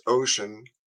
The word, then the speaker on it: Wouxun: With this little ocean, that's Wouxun, but it's pronounced ocean,